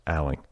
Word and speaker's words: Alling: But I was informed shortly after her arrival here last week that her name is actually pronounced Alling. It's Meredith Alling.